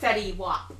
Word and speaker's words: Fetty Wap: Fetty Wap. – Fetty Wap.